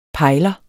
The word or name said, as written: pejler